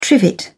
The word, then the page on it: trivet: British English